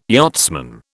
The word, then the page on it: yachtsman: British English